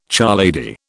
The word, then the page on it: charlady: British English